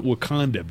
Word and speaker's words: Wakonda: Exactly the same, except there's an O. Wakanda. W-A-K-O-N-D-A. Wakanda. But let me tell you, you pronounce it Wakanda because I was so starved for golf,